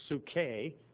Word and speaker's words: psuche: You know, in the New Testament, the Greek word is psuche. P is silent, but it's spelled P-S-E, P-S-U-C-H-E, and C-H is kind of like a K.